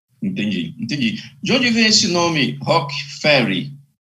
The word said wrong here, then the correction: Rogue Ferry: Tá, quase lá, quase um pouquinho. É Rogue Ferry, na verdade. Rogue. É como se fosse mercenário, né? – Ah, ok, tem razão.